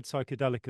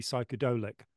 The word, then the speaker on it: psychedelic: I'm going to put one thing in, which is that we owe the word psychedelic, as I understand it, to Aldous Huxley. And he was very insistent that the proper word should be psychedolic. So we're all saying it wrong.